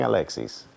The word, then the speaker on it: Alexis: Real quick, can I thank Felipe for something for saying my name in Spanish? No one ever does that. Thank you for saying Alexis.